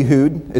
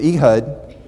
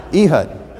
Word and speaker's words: Ehud: Some people say Ehud. It's wrong because it's Ehud because it rhymes with Judd, and he was left-handed. Ehud.